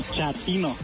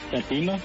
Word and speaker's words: chatino: Chapino. – ¿Chapino? – No, chatino. – Es como chato, ¿verdad? Chatino. – Sí, afinadero como chato, chatino.